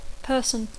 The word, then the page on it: person: English